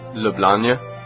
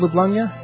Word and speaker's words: Ljubljana: We have differing ways of pronouncing it. How do you pronounce it? – Ljubljana. – Isn't that what I just said, Ljubljana? You had like a Y in there or something. – Ljubljana. – Ljubljana, okay. – I guess I pronounce it with a Y in the end part.